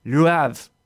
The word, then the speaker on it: Le Havre: My wife's family on her mother's side is from this town, and they say it in French, Le Havre. – I'm just going to let them say it like that then, because that's fantastic. – is that like Brett Favre? – Exactly.